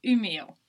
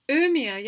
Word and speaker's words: Umeå: It's called Umeå. – Umeå, yeah, because I've seen that spelt and I was trying to figure out how to say it.